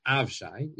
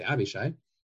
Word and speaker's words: Avishai: He takes the rest of the army and he entrusts it with his brother Avishai. Most places here included, he's actually pronounced Avshai, even though I think we always say Avishai.